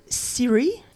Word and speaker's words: Psiri: It's an area called Syri. That's spelled P-S-I-R-I, or you may see it as P-S-Y-R-I.